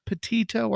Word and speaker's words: Petito: Petito.